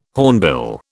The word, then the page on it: hornbill: British English